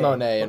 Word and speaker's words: Monet: It is Monet. – It's got a T on the end. – In French, the T is silent. – In French, you never pronounce the T.